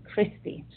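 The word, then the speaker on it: Christie: But my last name starts with a C, Christi, just like Christ.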